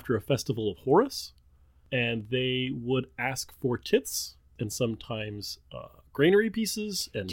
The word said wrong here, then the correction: tithes: Did you just say tits? – Tits. T-I-T-H-E. – Tithes. Tithes. It's pronounced tithes. It's not pronounced tits.